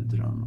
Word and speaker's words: Duran: Var det i Spanien som heter Duran också, tror jag. – Säger ni Duran men med betoning på A? – Duran.